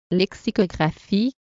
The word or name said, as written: lexicographie